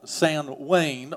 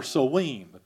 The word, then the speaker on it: Samhain: The Druids, about 3,000 years ago, the Druids had a pagan celebration called Samhain or Samhain, depending on who's pronouncing it. It sometimes is pronounced Samhain as well, but it rallied around the pagan god of the dead, Samhain.